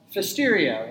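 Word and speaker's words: Pfiesteria: Okay, it's called Fisteria, another one of the mysteries of the English language is why is that pronounced Fisteria? Why do we even bother to put the P on the front of it? Okay, usually you'd expect it to be PH, but it's not, it's PF.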